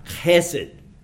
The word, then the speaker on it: Chesed: Chesed. And my Hebrew professor said, now I want you to just picture a camel spitting. And he said, that's how you can remember how to say this word.